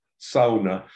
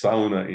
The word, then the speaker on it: sauna: Sauna, as you say in Finland, or sauna, as we say in many parts of the United States. Sauna. It's sauna in Finnish. – Sauna. Yeah, you pronounce the U. Yeah, we just go sauna.